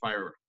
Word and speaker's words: FHIR: In the Fast Healthcare Interoperability Resources, or FHIR, pronounced FHIR, Peter, as you did very properly for it,